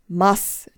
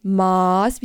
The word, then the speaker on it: Mass: Mass, nicht Maß wie das Maßband, sondern Mass.